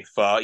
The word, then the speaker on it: pho: and you want to say pho because of the way it's spelled. – spelled. – Yep. – I actually feel silly when I say pho, even though I'm saying it right.